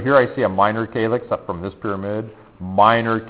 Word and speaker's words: calyx: We call this a minor calyx. That's how we pronounce it. That's a calyx.